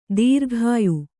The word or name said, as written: ದೂರಗೆಯ್ಯು